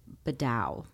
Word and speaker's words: Bedard: But my last name is Bedard, B-E-D-A-R-D. – Okay. – But if you pronounce that, it's Bedau, Jean Bedau.